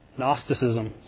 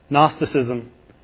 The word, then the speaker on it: Gnosticism: And so there were some really, really early who believe that this is Paul actually combating Gnosticism. Sorry, tripped over the word. Always want to pronounce that G. Gnosticism.